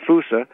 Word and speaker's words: fossa: It's spelled like fossa, like F-O-S-S-A, but pronounced fusa.